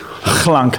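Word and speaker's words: hlanc: What's the Old English pronunciation again? – It's H-L-A-N-C. – Hlank. – Hlank. So loose hlank.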